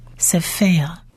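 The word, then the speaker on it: sefer (ספר): 字母，这个字听众朋友可能会对这个字会多一点好奇，因为其实我们在圣经里头说创世纪啦，创埃及纪啦，什么什么书卷啦，都是用 sefer 这个字， sefer，什么什么书都是 sefer 这个字。 sefer 三个字母，第一个字母是 samech，希伯来文字母 samech， samech 它其实发音上就是发 S 的音四四，而它下面平行的这两点的是母音 A A Say Say Say Say Say。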